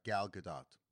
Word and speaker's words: Gal Gadot: Gal Gadot.